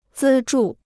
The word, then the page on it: 资助: zī zhù